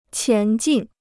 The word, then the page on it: 前进: qián jìn